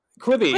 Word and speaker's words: Quibi: Quibi? – Quibi, yeah. – That's how you say it. Okay.